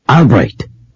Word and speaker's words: Albrecht: R-O-S-E-N-Z-W-E-I-G. – R-O-S-E-N-Z-W-E-I-G. Thank you. And your first name? – Albright. – I'm sorry, but you're going to have to spell that one, too. – A-L-B-R-E-C-H-T.